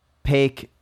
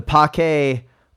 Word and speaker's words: Pake: So is the new Albion Privateer, formerly known as the Pake Seamute. I think it's pronounced Pake. – Pake. – Pake. Pake. P-A-K-E, and I apologize.